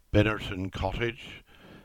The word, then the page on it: Binnerton Cottage: English